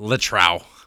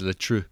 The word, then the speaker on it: Le Trou: No, it's not Le Trou. It is Le True, and that is for the whole.